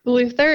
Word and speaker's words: Luther: Luther is L-U-T-H-E-R.